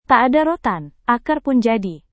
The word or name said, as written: tak ada rotan akar pun jadi